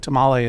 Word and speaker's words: tamal: But first of all, I noticed you pronounced what I would have said tamale as tamal. Is that the correct pronunciation? – Tamal is the singular.